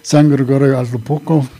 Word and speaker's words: San Gregorio Atlapulco: San Gregorio Atlapulco, Cosmovisiones, Cosmovisions.